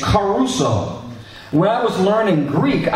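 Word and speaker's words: caruso (κηρύσσω): Because caruso, you know, Enrico Caruso, that's like the way it's pronounced, this Greek word.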